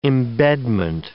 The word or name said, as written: embedment